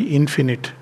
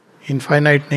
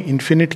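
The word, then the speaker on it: infinite: is इनफाइनाइट sorry इन्फिनिट अभी अभी मैंने नया नया प्रोनाउंसिएशन सीखा है इनफाइनाइट नहीं इन्फिनिट कहते हैं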